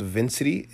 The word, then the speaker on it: Vinsity: We are still adding content to the platform as we speak, but I founded a new platform called Vincity. It's spelled V-I-N-S-I-T-Y.